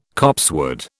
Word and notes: copsewood: British English

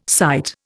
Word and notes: sight: Indian

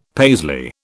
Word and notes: Paisley: British English

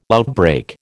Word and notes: outbreak: American English

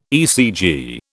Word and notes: ECG: British English

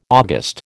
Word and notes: August: American English

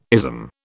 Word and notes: ism: British English

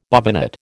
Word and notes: bobbinet: American English

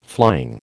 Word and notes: flying: American English

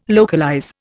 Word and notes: localize: Indian

